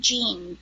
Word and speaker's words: Jeen: Okay, well, my first name is Bobby, B-O-B-B-I, and then Jean, J-E-E-N. – Okay. And so last name? – is Olson, O-L-S-O-N.